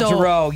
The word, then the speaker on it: Jarreau: It's J-A-R-R-E-A-U. Kind of like French, but it's not a French name. – That's right. That's what it is. Al Jarreau.